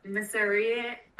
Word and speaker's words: Miserere: After hearing Gregorio Allegri's Miserere...